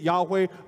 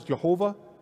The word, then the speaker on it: Yahweh: We say it Yahweh. Some people pronounce it as Jehovah.